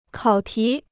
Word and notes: 考题: kǎo tí

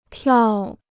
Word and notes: 跳: tiào

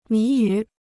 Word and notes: 谜语: mí yǔ

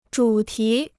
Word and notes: 主题: zhǔ tí

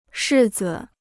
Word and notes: 柿子: shì zi

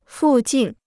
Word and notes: 附近: fù jìn

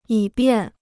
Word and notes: 以便: yǐ biàn

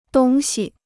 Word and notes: 东西: dōng xi

